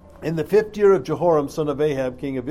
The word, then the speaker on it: Jehoram: Now, Jehoram, I said it wrong a minute ago, Jehoram was a king of Judah.